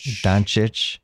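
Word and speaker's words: Dončić: This year it's Luka Doncic. Did I say that right? – Doncic.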